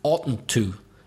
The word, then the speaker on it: oughtn't to: By the way, listeners, you may have been surprised at the pronunciation of oughtn't to. Yes, it does sound funny, but that's what it is.